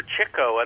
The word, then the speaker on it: Chico: However, Chico, and that's the correct pronunciation of his name, because he chased chicks.